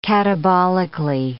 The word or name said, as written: catabolically